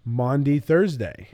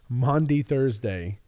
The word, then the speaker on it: Maundy Thursday: But this is going to come out on Maundy Thursday.